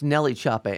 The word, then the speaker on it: NLE Choppa: It's Nelly Choppa. N-L-E is pronounced Nelly.